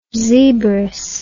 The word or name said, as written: zebress